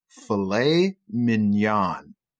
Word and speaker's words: filet mignon: So you just need to know the pronunciation is filet mignon. – Okay, 所以我们说的这个菲力就是一个从发音来的啊 ,filet, 然后 mignon, 拼法是 M-I-G-N-O-N, 不是 mignon, 是 filet mignon.